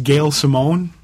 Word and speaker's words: Gail Simone: I think her name is Gail Simone. Am I pronouncing that correct? – yes, indeed you are.